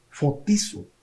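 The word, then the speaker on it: fotizo (φωτίζω): Esa palabra en griego es la, palabra, la iluminación, la palabra fotos, pero se escribe P-H-O-T-I-S-O, fotizo.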